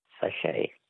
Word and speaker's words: sachet: Sachet. – A sachet. S-A-C-H-E-T. Well, they were too easy for you, so we're not asking you any more words that end with a silent T, mainly because there are no more questions in that category.